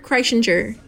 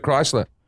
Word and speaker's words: Kreischer: Me and Burt Kreischer. – Burt Kreischer. – Burt Kaiser. It looks like Burt, but it's an R right there. I don't know if the R is silent.